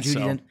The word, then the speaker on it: Judi Dench: Is that how you say her name? Dame Judi Dench. – Ugh, eye rolly, yes.